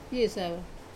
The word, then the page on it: piesava: wamesa